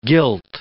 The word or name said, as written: gilt